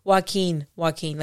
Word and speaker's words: Joaquin: Joaquin, like the J's. – Okay. – The J is silent.